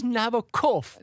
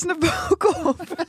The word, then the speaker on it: Nabokov: Het is Nabokov. – Dit is Nabokov.